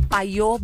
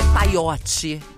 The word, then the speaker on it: Payot: É arroba paiô Brasil. Só que, ó, é PAYOT e Brasil. Paiô. É paiote Brasil. – Escreve paiote com Y, né? Mas a pronúncia é paiô Brasil.